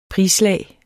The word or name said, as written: prislag